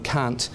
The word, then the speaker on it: Kant: Now, actually, as I understand it, most people in America and New Zealand and the UK pronounce his name Immanuel Kant. In actual fact, the A is pronounced as a U.